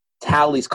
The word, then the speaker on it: Halley's: You ever heard of Haley's Comet? – Yes. – Halley's Comet now with two L's, short A.